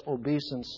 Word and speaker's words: obeisance: He gets flown around on Air Force One with required obeisance. I said it last week as obeisance, and that's a secondary pronunciation.